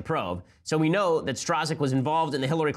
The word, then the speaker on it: Strzok: So, you recall, a lot of them come down to this one guy, Peter Stroke, Peter Strzok. It's spelled S-T-R-Z-O-K. I have no idea how to pronounce this, so I'll just pronounce it Schrock, Schrick. I'll just pronounce it differently every time, make fun of his name.